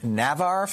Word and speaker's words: Navarre: In Navarre, Florida. Am I saying it right? – Yeah, Navarre, Florida.